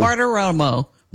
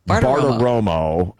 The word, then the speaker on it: Bartiromo: Also, Maria Barta, I always get her name mixed up when I try to say it. It's so many syllables. Baratomomo, Romo, Baratomomo. – Bartiromo. – Bartiromo. I always have a problem with that.